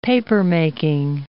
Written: papermaking

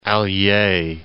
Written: Allier

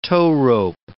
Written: towrope

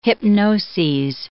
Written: hypnoses

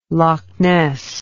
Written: lochness

